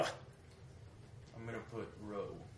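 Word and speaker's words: rho: Row. – Row. Every time you see this, it's a R.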